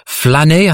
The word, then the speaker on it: flâner: Another one that appears often in these lists of untranslatable French words is flâner. I think it's pronounced like that, but it doesn't mean to make a flan.